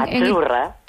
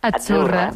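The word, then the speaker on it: Azzurra: La Z no es la Z como decimos en catalán tan sonora, no. Es achurra. – Muy bonito, muy bonito. – Es como pizza.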